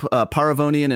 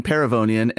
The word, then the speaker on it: Paravonian: Rob Paravonian, other letters. – You know, I just realized that I have been mispronouncing your name for years. – So have I. We accept both growing up, Paravonian and Paravonian.